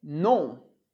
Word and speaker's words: non: Outra palavrinha importante é non. Non. Que tanto pode ser escrita com N, que significa não, non, como pode ser escrita com M, que significa nome. A pronúncia é exatamente a mesma.